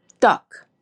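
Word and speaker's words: duck (떡): And to me, it's duck. Duck. So to me, I always describe it, it's like spelling it D-U-C-K. It's like it's a D sound, but it's like a sticky D.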